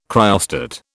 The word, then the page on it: cryostat: British English